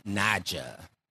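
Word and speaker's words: Naija: So our next song is called Pack Light by Queen Naya. I believe that's how you say her name. – Queen Naja. – Naja. Okay, okay, okay. Naja.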